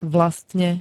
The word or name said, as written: vlastne